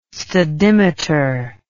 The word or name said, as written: stadimeter